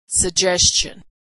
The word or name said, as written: suggestion